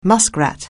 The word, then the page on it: muskrat: anglais